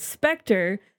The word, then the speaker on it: SPECTRE: Yes, Spectre.